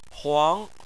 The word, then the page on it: huang: 2